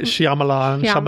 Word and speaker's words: Shyamalan: Night Shyamalan.